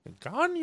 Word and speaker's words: Gagnon: Man, I'm usually on top of Mets spellings and pronunciations, but just think about how Adam Sandler would say his name. That's the clue there. Gagnon, you know. So that's the way to pronounce it.